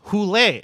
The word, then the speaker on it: Hole: Hole. It's supposed to be pronounced Hule. This is a Norwegian book series. So I do want to hear Matt Berry say Harry Hule.